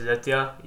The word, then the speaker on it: Aditya: My name is Aditya. You spell it A-D-I-T-Y-A.